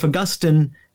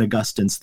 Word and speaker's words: Augustine: And they pronounce him Augustine also. – That's true. – That was always a mental flip becoming Catholic. The hardest part of conversion was to change how I pronounced Augustine.